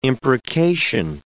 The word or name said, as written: imprecation